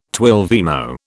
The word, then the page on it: twelvemo: British English